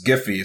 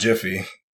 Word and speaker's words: Giphy: I say GIFs because you know the site, their name is Giphy. It's not Jiffy.